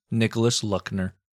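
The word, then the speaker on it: Nicolas Luckner: The far right flank, the Army of the Rhine, was led by General Nicholas Luckner.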